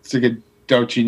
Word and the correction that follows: Schenectady: New York. – Oh, Schenectady.